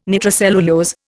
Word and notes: nitrocellulose: Indian

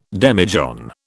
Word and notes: demijohn: British English

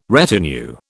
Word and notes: retinue: British English

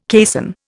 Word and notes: caisson: Indian

